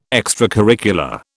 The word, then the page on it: extracurricular: British English